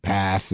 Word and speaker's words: PaaS: So, and, you know, so there's all these terms like SaaS and PaaS and okay, what are these? Am I pronouncing them correctly? – Yes, first? – I think I've never.